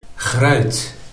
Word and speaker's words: gruit: So here is pronunciation. – Gruit. Gruit. – Okay. let me try. Grout.